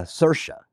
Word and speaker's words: Saoirse: And Freedom in Gaelic, is a Saoirse. Is how it's pronounced. When you look at the word, it doesn't look anything like Saoirse, just like most Gaelic.